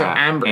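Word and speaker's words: Æmber: Well, A and E used to be a symbol called ash, and it's pronounced that way. Ah. – Okay, so amber.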